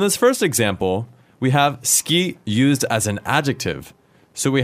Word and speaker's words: 好き: 好き is written S-U-K-I. But how do we pronounce it, Wakako-san?